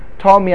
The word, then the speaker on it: Talmei: You spell it in English Ptolemy, but you don't pronounce a T. Talmei HaMelech,